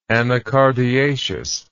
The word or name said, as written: anacardiaceous